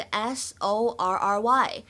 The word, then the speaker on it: sorry: 比如说，第一个，在英式发音当中呢，我们读作 sorry，sorry。而呢，在美式发音当中，它的这个元音呢会更加偏向于长元音的 o，就会变成 sorry，sorry。